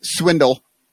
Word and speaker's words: Schwindel: What I don't understand, though, is so Schwindel, which Sugar says it's Swindle. And if anybody knows about mispronouncing a name with an extra sh in it, it's Bill Sugas. – Yeah, yeah, because it's really Sugas.